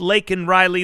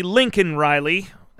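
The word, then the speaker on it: Laken Riley: And he pronounced Lakin Riley Lincoln Riley.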